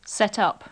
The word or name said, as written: set up